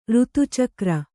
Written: ಋತುಚಕ್ರ

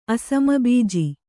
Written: ಅಸಮಬೀಜಿ

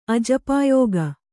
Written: ಅಜಪಾಯೋಗ

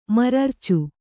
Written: ಮರರ್ಚು

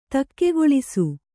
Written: ದೆಖ್ಖಾಳಿಸು

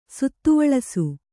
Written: ಸುತ್ತುವಳಸು

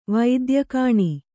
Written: ವೈದ್ಯಕಾಣಿ

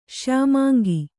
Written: ಶ್ಯಾಮಾಂಗ

